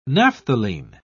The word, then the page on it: naphthalene: British English